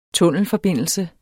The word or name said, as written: tunnelforbindelse